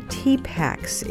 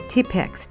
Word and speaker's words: Tipex: They're called the Tipaks. It's the English pronunciation of their real name, Tipex, T-I-P-E-X, which means whiteout.